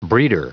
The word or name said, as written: breeder